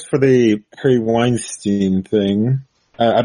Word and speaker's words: Weinstein: the whole Weinstein, or if some people might pronounce it Weinstein,